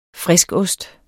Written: friskost

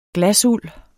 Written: glasuld

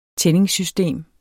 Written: tændingssystem

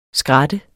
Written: skrat

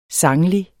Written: sanglig

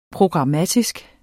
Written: programmatisk

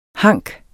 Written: hank

